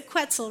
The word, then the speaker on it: quetzal: This guy's a quetzal. Rhymes with pretzel.